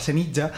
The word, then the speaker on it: Senija: Y la villa de al lado, que es Senija, que es un pueblo, voy a pronunciarlo como toca, Senija, que es un pueblo de 500 habitantes o así.